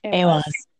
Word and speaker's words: AWAS: And so for me, then looking ahead at, you know, December 28th, when AWAS, did I say that right? – AWAS.